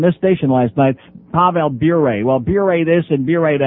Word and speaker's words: Bure: By the way, Terry Murray, before you leave on that trip, his name is Bure, okay, not Bure.